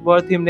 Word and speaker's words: Wertheim: ये हमारे हर्बर्ट वर्थ क्या बोलते हैं, हर्बर्ट वर्थिम ने कहा है, इन्वेस्टर है काफ़ी अच्छे, डॉक्टर वर्थिम ने एक्चुअली, इनका इंस्टाग्राम है @DR Wertheim, WERTHEIM।